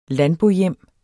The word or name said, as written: landbohjem